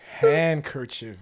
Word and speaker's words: handkerchief: H-A-N-D. – What? – Handkerchief, but the D is silent, so it's handkerchief.